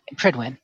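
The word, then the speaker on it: Prydwen: Is that pronounced Pridwin or Pridewin? – Pridwin. – Pridwin, okay.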